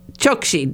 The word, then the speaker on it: Chokshi: Chokshi.